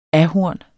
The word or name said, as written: ahorn